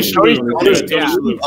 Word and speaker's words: Shalhoub: Mr. Shalhoub, how do you say his last name? – Tony Shalhoub.